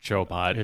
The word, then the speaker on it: Chobot: in the Wikipedia, and I'm like, it's not pronounced Chobot. – It is.